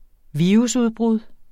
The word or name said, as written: virusudbrud